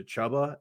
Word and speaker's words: Chuba: But I just prefer him to Chuba. And so if you're – if when you say Chuba's name, if it rhymes with Hubba Bubba Gum, you said it wrong.